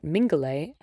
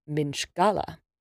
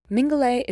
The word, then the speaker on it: Mingulay: It is strange to call such a tiny island in the Outer Hebrides Great Island, so it's understandable why some people would think that Mingulay actually comes from Minchgala. There are a few folk singers who pronounce it that way in their renditions of the song, but the etymology doesn't line up.